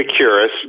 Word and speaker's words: Epicurus: How much of the life of Socrates or Epicurus, how do you say his name, that philosopher? – Epicurus, not to be confused with the website Epicurus, common misunderstanding.